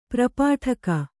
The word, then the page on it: ಪರ್ಪಾಟಕ: parpāṭaka